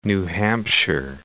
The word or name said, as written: New Hampshire